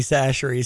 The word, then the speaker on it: Chachere: I did spell it correctly. Good for you. C H A C H E R E apostrophe S from oh.